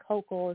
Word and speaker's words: Hochul: I'm trying to figure out, so remember the Hochul is how you say her name.